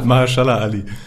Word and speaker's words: Mahershala Ali: Man muss schon sagen, die dritte Staffel fand ich schon, war, ich glaube, mit Mahershala Ali? – Nein, wie spricht man den aus? – Ja, das war Close Enough. – Mahershala Ali. – Ja, genau das.